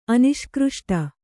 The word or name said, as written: ಅನಿಷ್ಕೃಷ್ಟ